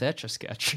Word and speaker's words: Etch-a-Sketch: My favorite thing about this episode is that none of us know how to pronounce Etch-a-Sketch. – What yeah, is it? Yeah, I keep saying exo, like exoskeleton. – You say exo-sketch, you said itchy-sketch.